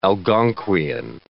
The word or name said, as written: Algonquian